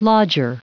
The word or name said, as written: lodger